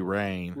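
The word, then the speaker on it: Rahne: Now, how do you pronounce the head coach's name? Rain? – Yeah, Ricky Rain.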